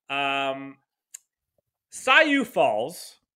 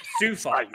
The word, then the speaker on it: Sioux Falls: Sayu Falls has brought about... – Sioux Falls.